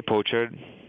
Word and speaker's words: pochard: is it pochard or poachard? – I say pochard.